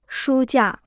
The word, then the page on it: 书架: shū jià